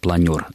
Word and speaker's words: планер: По поводу планер, мы уже многократно говорили, что слово заимствовано из французского языка, и там этот суффикс звучит как «эр», поэтому правильное произношение будет «планер», и, соответственно, прилагательное образовано от него это «планерное».